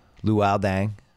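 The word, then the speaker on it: Luol Deng: You're saying, like, Lowell Dang, Luau Dang, Luau yes.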